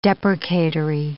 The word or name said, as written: deprecatory